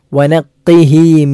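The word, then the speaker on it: വനഖൈഹി (وَنَقِّهِ): ഒന്ന് വനഖൈഹി. വനഖൈഹി എന്നത് ഖാഫാണ്. നേരത്തെ അക്കിരിം എന്ന് പറഞ്ഞിടത്ത് കാഫ് ആയിരുന്നു. ഇവിടെ ഖാഫ്, പുള്ളിയുള്ള ഖാഫാണ്. അത് അണ്ണാക്കിൽ നിന്നും പുറപ്പെടേണ്ട അക്ഷരമാണ്.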